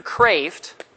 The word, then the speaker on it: Kreeft: The second book I want to show you guys is a new one, and it's by Peter Kraft. Peter Kraft. Peter K-R-E-E-F-T. So you pronounce it Kraft, like Beethoven. You know, two E's sounds like an A.